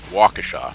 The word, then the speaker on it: Waukesha: So Sefton pointed out that it's pronounced Waukesha, but I grew up in New York. What the hell? Waukesha is the way it looks, so that's how I'm going to say it.